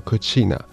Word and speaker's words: cucina: 今天是日本的意大利料理日，因为意大利的厨房这个单字发音叫做 cucina，而这个 cucina 就有点类似日文发音的 917 这三个数字，所以日本他们也有受到意大利的一些饮食文化的影响，他们就把 9 月17 号定为意大利料理日。